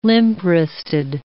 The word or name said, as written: limp-wristed